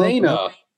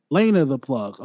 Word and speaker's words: Lena the Plug: With him and how you pronounce her name? Laura the Plug, Lana the Plug, Lena. – Lena the Plug.